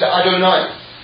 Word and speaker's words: Adonai: We pronounce it Adonai. That's how we say it.